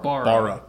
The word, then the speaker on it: Barra: We have a Barra. Did I say that right again? I'm sorry. – Like a metal bar. – Bar. – Bar. – Barra.